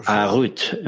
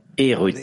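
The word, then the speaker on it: Erut (חירות): Harut gravé, ne prononcez pas Harut, mais Erut, liberté.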